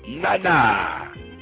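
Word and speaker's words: Nana (なな): Nana. – N-A-N-A.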